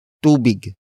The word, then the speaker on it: tubig: It is the object marker that introduces what the person is giving to the beneficiary, and that is tubig. Tubig. Tubig. Tubig is spelled as T-U-B-I-G.